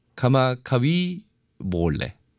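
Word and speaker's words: 카마카비 몰레 (Kamakawiwoʻole): 카마카비 몰레. 어, 보올레군요. 어, 그 W를 V로 발음을 하는 거구나.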